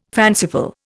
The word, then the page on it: fanciful: Indian